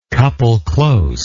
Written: couple-close

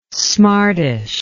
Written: smartish

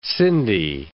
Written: Sindhi

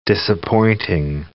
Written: disappointing

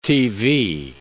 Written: TV

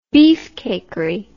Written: beefcakery

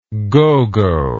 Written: gogo

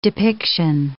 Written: depiction

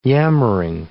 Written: yammering

